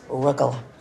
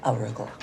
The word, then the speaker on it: arugula: Oh, it's arugula, not arugula.